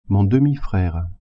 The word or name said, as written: demi-frere